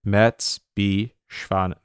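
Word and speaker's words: Merz b. Schwanen: Yeah, Mertz B. Schwanen. How did you pronounce it? – Mertz B. Schwanen. Mertz B. Schwanen. – Schwanen, yeah.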